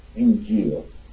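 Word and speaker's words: Injil (إنجيل): and it's pronounced in Arabic, Injil.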